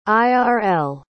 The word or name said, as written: irl